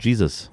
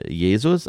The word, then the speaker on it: Gzuz: Wird ausgesprochen Jesus. Und Donuts nennt sich das Lied. Er hat einen bürgerlichen Namen, nämlich Christopher Jonas Klaus. Geboren am 29.1688 in Hamburg. Deutscher Rapper, Künstlername englisch ausgesprochen, nämlich wie Jesus, also Jesus.